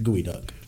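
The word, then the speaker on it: geoduck: That is one of the best episodes of Dirty Jobs I've ever seen in my life, and that's how I know it's pronounced gooey duck.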